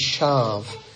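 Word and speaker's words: shav (שָׁוְא): And then this word in vain, the pronunciation is shav.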